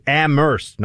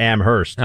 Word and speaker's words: Amherst: You don't pronounce the H, folks, just so you know. That's always the giveaway. Amherst, not Amherst.